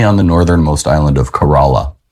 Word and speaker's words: Corolla: Oh, is that how you pronounce it? – It is. – Was I always pronouncing it wrong when we were there? – Well, we both thought it was Corolla, like the car, but we were wrong.